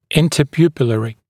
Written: interpupillary

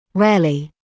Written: rarely

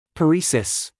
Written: paresis

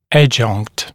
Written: adjunct

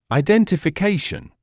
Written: identification

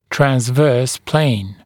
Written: transverse plane

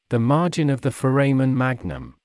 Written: the margin of the foramen magnum

